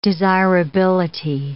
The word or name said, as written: desirability